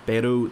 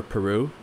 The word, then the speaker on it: Peru: We're not doing too well on the Pacific coast of South America, from Peru down to the middle coast of Chile. That's how you pronounce those damn countries. It's not Chile or Peru. It's Peru and Chile.